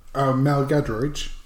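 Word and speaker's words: Giedroyc: And we also have I can't pronounce her surname. Just pronounce her surname for me. – Mel Gadridge. – Thank you.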